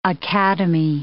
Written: academy

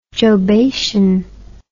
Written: jobation

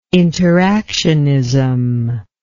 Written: interactionism

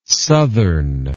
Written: sothern

